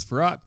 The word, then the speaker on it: Frerotte: I'm a big fan of Gus Frerotte because he has the seldom seen silent R in his last name.